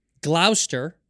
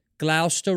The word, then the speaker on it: Gloucester: It's Gloucester, right? Is how you pronounce it. Gloucester Road, but it's spelled Gloucester.